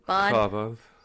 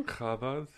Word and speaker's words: Chabad: Chabad. – Say that again? – Chabad.